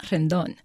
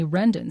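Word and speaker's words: Rendón: The third-generation Mexican-American usually pronounces his name Rendon, not Rendon. He wonders if it might have to do with people calling his family Rendon since the 1920s, when the first of his grandparents arrived.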